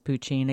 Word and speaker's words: poteen: So thousands of years of distilling this poutine. Again, it's spelled P-O-T-E-E-N,